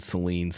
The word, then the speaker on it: Saline: I said it like very hard. Like I said saline, like saline solution. Am I pronouncing it wrong? – It's spelled the same. Yeah, it's spelled the same. But and so when people aren't familiar with it, they say saline. And I'm just like way over correcting people. I'm like, no, saline, damn it.